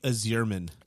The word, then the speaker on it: Yzerman: I believe that it's pronounced Steve Yzerman.